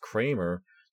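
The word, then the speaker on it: Kremer: Okay, it's spelled with an E, not with an A, but it's pronounced Kramer.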